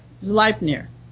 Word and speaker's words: Zleipnir: This is by Zleipnir. Is that how it's pronounced? – In German pronunciation, yeah. – Yeah, all right. Zleipnir. That's what I thought.